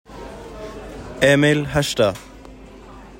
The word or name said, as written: emil herstad